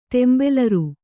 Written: ತೆಂಬೆಲರ್

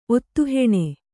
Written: ಒತ್ತುಹೆಣೆ

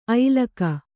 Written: ಐಲಕ

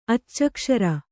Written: ಅಚ್ಚಕ್ಷರ

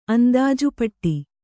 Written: ಅಂದಾಜುಪಟ್ಟಿ

